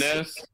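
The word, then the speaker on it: Enes: Ennis Kanter. – See, Ennis. Ennis.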